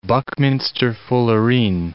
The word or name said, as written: buckminsterfullerene